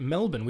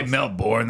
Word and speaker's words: Melbourne: And we know how to say it, Melbourne. We don't say Melbourne. – Yeah, we don't say Melbourne. That's weird. Melbourne.